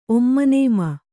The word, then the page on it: ಒಮ್ಮನನೇಮ: kannada